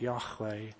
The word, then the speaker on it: Yahweh: And the covenant name for God in the Old Testament that we think is probably pronounced something like Yahweh, means I am.